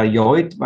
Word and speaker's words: Lloyd: Die Internationalität war eben dann der Name Lloyd, wobei er da nicht an den spanisch sprechenden Raum gedacht hat, weil da heißt es ja Lloyd, weil Doppel-L dort als J ausgesprochen wird.